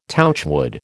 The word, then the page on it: touchwood: American English